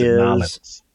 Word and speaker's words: New Orleans: And Nollins. You gotta pronounce it Nollins.